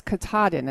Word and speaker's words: Katahdin: So it's Katahdin.